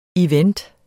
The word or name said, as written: event